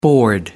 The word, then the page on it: board: American English